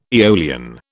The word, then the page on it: aeolian: British English